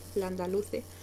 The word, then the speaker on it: Landaluce: is actually not that hard. It's just how you see it, it's pronounced right, Landaluce. She's usually a center back, so I was thinking it was gonna be a 3-5-2.